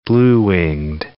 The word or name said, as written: blue-winged teal